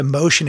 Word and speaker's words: Mocean: So you kind of change your name, but they changed your name to Motion. It's spelled MOCEAN. So like the ocean, but with an M on the front of it.